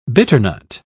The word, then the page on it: bitternut: British English